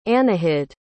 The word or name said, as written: anahid